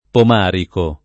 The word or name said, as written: Pomarico